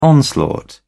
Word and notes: onslaught: British English

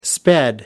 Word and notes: sped: British English

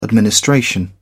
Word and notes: administration: British English

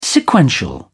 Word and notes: sequential: British English

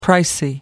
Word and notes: pricey: American English

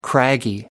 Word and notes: craggy: American English

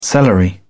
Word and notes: celery: British English

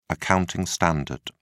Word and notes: accounting standard: British English

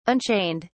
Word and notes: unchained: British English

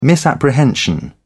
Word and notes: misapprehension: British English

